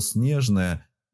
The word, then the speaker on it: Снежное: А вот название города Снежное, наоборот, с ударением на последний слог.